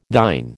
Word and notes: thine: American English

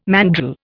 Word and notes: mandrel: Indian

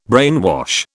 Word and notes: brainwash: British English

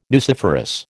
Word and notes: nuciferous: American English

